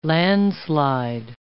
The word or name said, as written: landslide